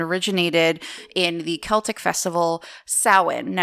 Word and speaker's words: Samhain: Now it's spelled like you would say Samhain, but it's Samhain. – Okay. – S-A-M-H-A-I-N, because, you know, the Celts just love to write it one way and say it another.